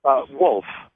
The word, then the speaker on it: Wolf: I'm Wolf. I'm in the Lincoln, Delaware area. – I'm sorry. What was your name again? – Wolf. W-O-L-F.